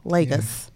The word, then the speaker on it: Lagos: So it's a Portuguese name, so it could be Lagos. However, it was British ruled, so there you go, the long A, Lagos.